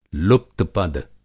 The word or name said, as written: लुप्त-पद